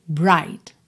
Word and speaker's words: bright: Esse bright tem ali um G no meio, né, GH, que não é pronunciado, tá? Então aqui a gente tem bright, bright. Pode ignorar completamente o GH ali no meio.